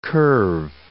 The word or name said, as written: curve